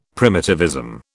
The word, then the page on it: primitivism: British English